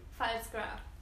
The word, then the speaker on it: Pfalzgraf: Pfalzgraf.